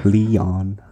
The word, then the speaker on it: Leon: I say it in a very American way, I think. Leon.